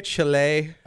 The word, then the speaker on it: Chile: Is that how we say it? Chile? – I think that's how the natives say it. Yeah. – I used to just say it like the restaurant as a Chile. – Kid. Yeah. I was like, Chili's minus the S.